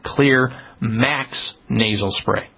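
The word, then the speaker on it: Xlear: If it's organisms that we need to clear out up there, and clear, by the way, is spelled with an X, X-L-E-A-R, the X stands for xylitol, one of the components, because their rescue formula has all those antimicrobials in it that help kill off the fungus, the yeast, and the mold.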